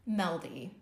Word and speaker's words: Mjelde: Meldy. – Meldy. – Okay. Just ignore the weird J.